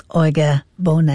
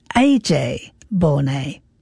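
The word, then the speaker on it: Euge bone: And of course, Oiga Bone is the classical, the classical Latin pronunciation, ecclesiastical Latin, I'm told, is Ege Bone.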